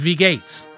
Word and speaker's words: wie geht's: It's pronounced wie geht's.